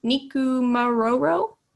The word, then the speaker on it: Nikumaroro: Nikumaroro. Alina? – Yeah, I think you pronounced that correctly.